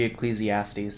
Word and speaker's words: Ecclesiastes: Ecclesiastics. – Ecclesiastics. – Or it'd be Ecclesiastes. That's a book in the Bible.